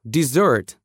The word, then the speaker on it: desert: А теперь сместим ударение на второй слог. Dessert, что, кстати, очень похоже по произношению на десерт.